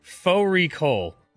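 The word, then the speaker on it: fårikål: Faux recall, which is a mutton and cabbage. It's spelt like far recall.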